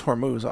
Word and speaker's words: Hormuz: He's now he mispronounces the Strait of Hormuz. I think he called it Hormuz or something, but he means Hormuz, obviously.